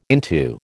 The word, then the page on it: into: American English